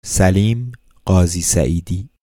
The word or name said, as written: Salim Ghazi Saeedi